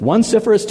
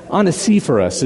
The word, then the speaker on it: Onesiphorus: And they said, is that one Sipherus, two Sipherus, three Sipherus, four? Again, if you look at it, it looks like one Sipherus, okay? Onesiphorus is his name.